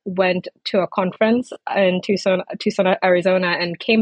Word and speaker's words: Tucson: Tucson.